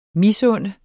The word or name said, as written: misunde